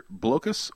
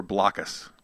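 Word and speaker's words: Blokus: And a game called, in this game, you can pronounce one of two different ways. I have not heard anything definitive on pronunciation. The game I like to use for teaching abstract thought is called either Blocus or Blockus, your choice.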